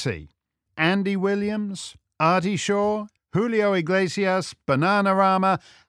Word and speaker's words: Julio Iglesias: Julio Iglesias.